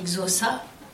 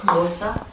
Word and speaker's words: Xhosa: xhosa. Enfin, qu'il se prononce d'ailleurs. – Xhosa. – Xhosa.